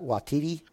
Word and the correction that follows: Taika Waititi: Uh, Taika Waititi. – Taika Waititi, yeah.